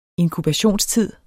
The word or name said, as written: inkubationstid